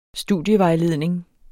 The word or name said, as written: studievejledning